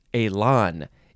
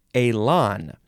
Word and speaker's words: élan: Elan. Yes, Elan. E-L-A-N. Accent on the first, on the E, goes whoop. Elan.